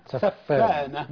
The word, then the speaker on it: سفانة: سفانة بمعنى لؤلؤة يا أستاذ محمد. سفانة بمعنى اللؤلؤة، بغير سفانة اللي هي صناعة السفن. فالنطق الصحيح لها سفانة.